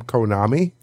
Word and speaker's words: Konami: I want to say the name rhymes with Momami or something like that. – I was going to say it rhymes with economy. And given how the U.S. economy is doing right now, it's more befitting. But no, I think it's just like Soulja Boy. Most people call them Konami.